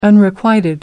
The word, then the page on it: unrequited: American English